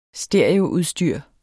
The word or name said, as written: stereoudstyr